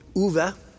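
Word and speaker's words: Ove: The book is a man called Uwe. I've always called it Ovi. It's not. I checked the internet. And it's Uwe or Uwe is the way you pronounce that.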